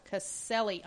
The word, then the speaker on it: Caselli: You know, that series was written by Kelly Thompson, and the artist was Stefano Calesi. – Okay. – Caselli. I'm sorry, Michael.